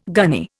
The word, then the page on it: gunny: Indian